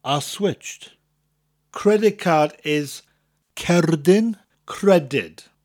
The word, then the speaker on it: cerdyn: It's spelt C-E-R-D-Y-N, the first word, and pronounced cerdyn. Although the first part of the word cerdyn sounds a lot like card, cerd, card, the R is pronounced differently. You need to roll that R in Welsh, whereas it's a lot softer in English. Card, cerd, that R, rolling R sound. Cerdyn. So we have cerdyn, followed by credyd, spelt C-R-E-D-Y-D.